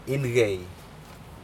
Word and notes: incei: Aneityum

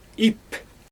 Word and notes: ip: Xyzyl

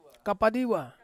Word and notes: kapadiwa: Ansus

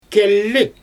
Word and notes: kelli: Xyzyl

